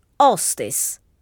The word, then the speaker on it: hostess: Well, we borrow that same word from the English language, but we say hostess, as if the H were not there.